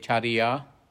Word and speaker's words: cariya: Actually, that's called C-A-R-I-Y-A, charya, charya.